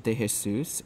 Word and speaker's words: De Jesus: You can look me up, Daniel De Jesus, and my last name is spelled D-E space, capital J-E-S-U-S.